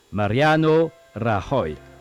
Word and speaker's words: Mariano Rajoy: moi je vais le prononcer à l'espagnol, bien sûr, je me souviens un petit peu de mes cours, c'est pas évident, mais c'est Mariano Rajoy.